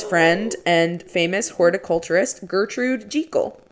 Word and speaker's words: Jekyll: The proper way to say this Scottish last name is really Jekyll. – Jekyll? – Yeah, not Jekyll, but Jekyll.